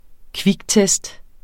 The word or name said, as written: kviktest